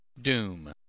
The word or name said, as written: doom